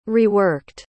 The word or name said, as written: reworked